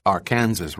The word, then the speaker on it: Arkansas: Arkansas, pronounced Arkansas River, on the north by a highway, Route 50, and on the east and west by prairie lands and wheat fields.